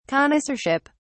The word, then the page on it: connoisseurship: British English